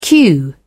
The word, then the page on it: cue: British English